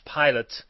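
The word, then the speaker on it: Pilat: And it's this one legend goes that Pontius Pilate is from the Pilate, if you pronounce it in the English way, or Pila, if you pronounce it in the French way, which is just south of Lyon.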